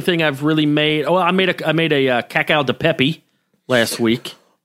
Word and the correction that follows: cacio: Oh, cacio di pepe. Yes. – Yeah, cacio. Yeah, I can never pronounce it. But I made that. – Two C's together, generally a CH.